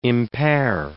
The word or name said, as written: impair